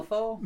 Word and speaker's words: Malifaux: M-A-L-I-F-A-U-X. – Okay. That's so, Malifaux? – Malifaux.